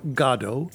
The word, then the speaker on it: Godot: 1985, actors Chris and Vincent O'Neill, who you may have heard of, appear in a touring production of Waiting for Godot, or Godot, if you prefer the Irish pronunciation, and they do it in the basement of the Airways Motel in Cheektowaga.